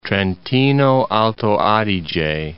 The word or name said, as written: Trentino-Alto Adige